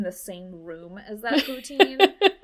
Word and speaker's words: poutine: The poutine.